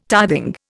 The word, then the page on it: tithing: Indian